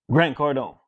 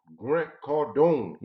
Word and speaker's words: Grant Cardone: Grant Cardone. – Grant Cardone.